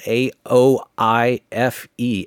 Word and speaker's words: Aoife: Age of Apathy is the record from Aoife O'Donovan, and her first name is spelled A O I F E. A O I F E, pronounced Aoife O'Donovan, Age of Apathy.